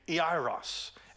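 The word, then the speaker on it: Iairos (Ἰάϊρος): And we're going to contrast him in a few moments with this woman, but his name is actually pronounced in Greek, Iairos.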